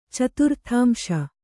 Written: ಚತುರ್ಥಾಂಶ

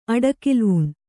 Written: ಅಡೆಕಲುವಣ

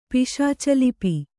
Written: ಪಿಶಾಚಲಿಪಿ

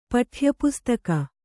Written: ಪಠ್ಯಪುಸ್ತಕ

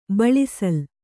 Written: ಬಿಳಿಸೂಲಿ

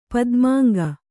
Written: ಪದ್ಮಾಂಗ

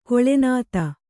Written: ಕುಲೀನತೆ

